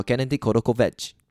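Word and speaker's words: Gennady Korotkevich: 然后他的名字叫做我特别为了发发音他的呃，为了念他的名字，还特别上网去查发音。他是白俄罗斯人，叫 Gennady Kodokovich，真的就是这样念。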